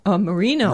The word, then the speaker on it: Marino: And it's basically Marano, which is a town south of Rome, and they have every year a grape festival. – How do you spell it? – M-A-R-I-N-O. – Marino. – Marino, sorry.